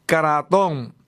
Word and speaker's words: Kratom: Así que si lo ha escuchado por ahí, sepa que se llama Kratom, así como lo pronuncio, K-R-A-T-O-M, Kratom.